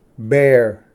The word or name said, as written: ᏲᎾ